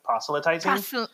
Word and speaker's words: proselytizing: proselytizing. I think I said that right. Proselytizing. – Proselytizing. Yeah.